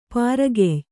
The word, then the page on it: ಪರಕೀಯೆ: Kannada